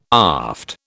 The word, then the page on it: aft: British English